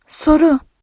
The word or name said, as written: soru